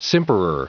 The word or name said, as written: simperer